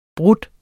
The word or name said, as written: bryder